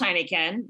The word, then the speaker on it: Heinichen: And her last name is pronounced Heineken. Now, it's not spelled like the beer. I'm sure you know how to spell it, Jackie. With I'm from Milwaukee. I can spell it. – H-E-I-N-I-C-H-E-N. So it looks like Heineken.